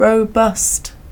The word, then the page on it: robust: British English